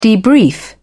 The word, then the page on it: debrief: British English